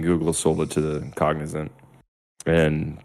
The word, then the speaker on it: Cognizant: Like one of the big sponsors for Aston Martin, I believe, the Formula One team, is Cosignat or something like that. It's COZ. I don't know if I'm saying it right. Yeah. Cognizant. – Cognizant.